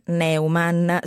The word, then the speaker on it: Neumann: Allora, lui si chiama Andrés Neumann, si pronuncia così il cognome perché è di origini tedesche, eccetera.